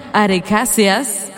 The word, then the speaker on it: arecáceas: Las palmeras, mejor conocidas en el mundo de la biología y de la ciencia, perfectamente, ajá, como las arecáceas. ¡Ay, lo dije bien!